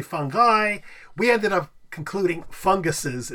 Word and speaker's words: fungi: The I would be pronounced E, so it'd be fungi.